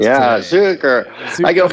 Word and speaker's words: Zuker: Half my family pronounces it, no one pronounces it Zucker, but some pronounce it Zooker. And then I pronounce it Zooker because it's just like it's spelled that way, so it was just easier to get people to spell it the right way.